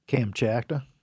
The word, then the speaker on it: Kamchatka: Kamchatka? – Kamchatka. – I think it's that. – Yeah, Kamchatka. Kamchatka? There's a vodka called that. – Russia's Kamchatka.